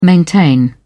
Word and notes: maintain: British English

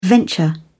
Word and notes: venture: British English

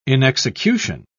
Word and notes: inexecution: British English

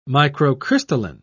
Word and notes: microcrystalline: British English

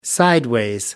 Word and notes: sideways: American English

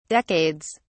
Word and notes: decades: British English

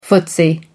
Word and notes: footsie: American English

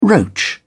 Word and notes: roach: British English

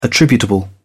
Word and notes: attributable: British English

